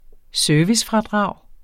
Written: servicefradrag

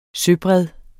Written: søbred

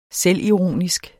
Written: selvironisk

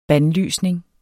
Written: bandlysning

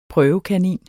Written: prøvekanin

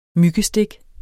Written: myggestik

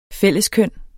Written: fælleskøn

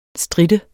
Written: strit

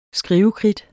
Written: skrivekridt

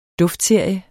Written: duftserie

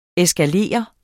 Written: eskalere

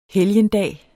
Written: helgendag

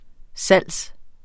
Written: salgs

